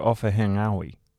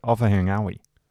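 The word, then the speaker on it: Ofahengaue: The NGA in Maori or Pacifica is said like the NG in the English word singer. Therefore, we should not be hearing any hard G sounds, especially when you're talking about someone like Joe Offahengawi.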